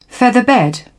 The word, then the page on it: featherbed: British English